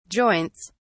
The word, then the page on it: joints: British English